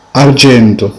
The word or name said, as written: argento